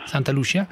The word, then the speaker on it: Santa Lucia: Santa Lucia, com'è la pronuncia? – Santa Lucia.